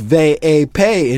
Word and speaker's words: VIP: As he approaches the red rope of the VIP, pronounced Ve A Pay in Spanish, oh my God.